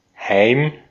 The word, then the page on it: Heim: /haɪ̯m/